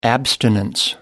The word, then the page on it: abstinence: American English